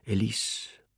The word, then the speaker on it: Elise: Ihr Name, auf der zweiten Silbe betont, rattert in seinem Kopf wie der Rhythmus, den der Zug hält. Rattam, rattam. Elise, Elise.